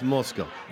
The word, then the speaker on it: Moscow: correct pronunciation of M-O-S-C-O-W. – Okay. – The O-W sound can be pronounced as cow, how, pow, or as the long O in show, toe, blow. – Okay. – You just pronounce Moscow. – Yeah. – Moscow. That's how. Yeah. – That's how we pronounce it in the Soviet Union.